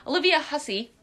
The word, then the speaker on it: Olivia Hussey: I hope this is how you pronounce her name, because otherwise I'll feel like an ass. Olivia Hussey. – Yes.